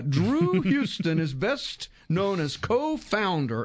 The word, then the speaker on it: Huston: Houston, yeah. Mm-hmm. It's pronounced just like the road in New York. Andrew W. Houston.